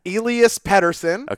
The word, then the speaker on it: Elias Pettersson: It's Elias Pettersson.